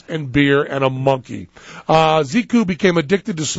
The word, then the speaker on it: Xiku: Ziku, is that how you pronounce X-I-K-U? – Yeah. – Ziku.